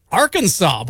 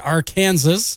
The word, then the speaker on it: Arkansas: Study also looked at the states that were hardest for Americans to pronounce, and Arkansas, believe it or not, took the top spot. A lot of people wondering why it's not pronounced Arkansas, I guess.